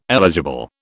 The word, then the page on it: eligible: British English